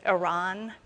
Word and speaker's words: Iran: Yes, that is how you say it. Iran, not Iran. I don't know where you ran, but what.